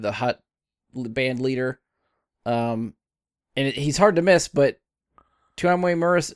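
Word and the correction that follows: Temuera Morrison: I can't never say his name, Tamura Morrison, the guy that plays Boba Fett, was Boba Fett in this series. – Tamura, you had it okay.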